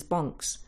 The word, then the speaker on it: Banks: The Tilsley Banks, locally pronounced as Bonks, where the foothills of the Pennines begin.